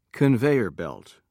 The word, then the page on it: conveyer belt: British English